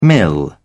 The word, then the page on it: mill: British English